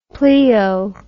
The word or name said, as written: pleo